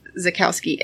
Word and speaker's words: Czajkowski: So as far as our bad nurse story for this week, this is the story of Dennis Zakowski. I guess is how you say that. Does that sound right? – Yeah. – Okay. Dennis Zakowski, and it's spelled C-Z-A-J-K-O-W-S-K-I.